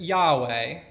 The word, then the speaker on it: Yahweh: Now, I've got on here, most research that I've heard thinks that Yahweh, and I think you've probably heard Yahweh, which would be Y-A-H-W-E-H, is how that name would have pronounced.